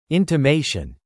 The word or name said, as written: intimation